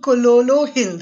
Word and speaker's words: Kololo Hill: Hi, so today we have with us Neema Shah, who's written Kololo Hill.